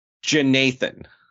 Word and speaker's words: Jeenathan: There's this guy's name that is spelled J-E-E-N-A-T-H-A-N Williams, but it's Jonathan.